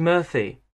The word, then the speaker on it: Murphy: J-O-H-N. Yes, J-O-H-N. And my surname's Murphy. M-U-R-P-H-Y.